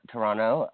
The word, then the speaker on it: Toronto: Oh well, well, you know, I I I want to go to Toronto, Toronto, um, and I know to not pronounce the T.